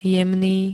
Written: jemný

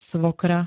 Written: svokra

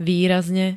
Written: výrazne

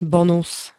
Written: bonus

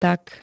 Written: tak

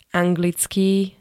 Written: anglický

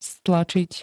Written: stlačiť

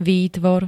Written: výtvor

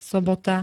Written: sobota

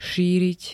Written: šíriť

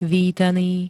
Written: vítaný

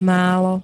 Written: málo

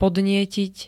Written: podnietiť

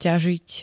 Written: ťažiť

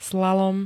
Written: slalom

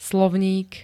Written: slovník

